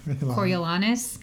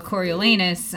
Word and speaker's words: Coriolanus: apparently in the ancient sources, the A in Coriolanus would have been pronounced Onus, Coriolanus. And yet, you know, the English and we go Coriolanus, and so it's like the butt play.